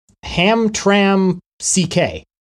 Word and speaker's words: Hamtramck: So it is Ham Tram CK. Like the Hamtramck. – Oh, good old Hamtramck City.